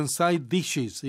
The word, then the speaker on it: side dishes: 그 이제 발음은 side dishes 이렇게 읽어도 좋아요. 같은 자음이 겹치면 한 번 발음하죠.